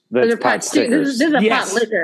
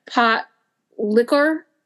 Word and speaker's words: potlikker: And it's not even spelled like, it's pronounced like pot liquor almost. – Oh, I thought you said sticker. My bad. – Oh, yeah. It's POTLIKKER. Pot liquor.